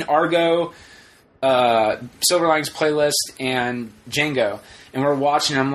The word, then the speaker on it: Django: Sarah wasn't annoyed when we saw Django, but I don't... – Django. Yeah. Not the Django. – I know. It's just like the trailer says, it's the D is silent.